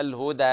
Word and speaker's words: الهدى: الهدى يعني قرأتها مثل التقليل، الهدى هذا كذا يقرأ ورش وبعض القراء. فانتبه لفتح الفك قليلا، الهدى هكذا.